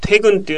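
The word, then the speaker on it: 퇴근: テグヌウェ、テグンというのが退勤で、フーというのが後という漢字、そのままフーと読むわけですけど、退勤後にということですね。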